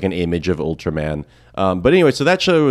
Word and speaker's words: Ultraman: The other clip I want to play though is that, so then there was this popular Japanese show for kids called Ultraman, which is kind of pronounced like Ultraman, which you might want to know for the clip.